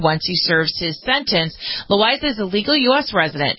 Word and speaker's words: Loaiza: The story of Esteban, is it Loaiza? How do you pronounce this guy's name? – Loaiza. – I believe, right.